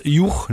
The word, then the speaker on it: Hugh: Eine Zusammenziehung aus Hugbert, Hugubert, aus Hubert, kennen wir, Hubert, auch im Englischen, wie spricht sich das aus? Juch, ne? H-U-G-H geschrieben, alter Vorname, oder überhaupt noch moderner Vorname.